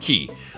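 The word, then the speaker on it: Cay: you said Key. I always thought Royal Caribbean used K, but have now they officially switched to the correct pronunciation.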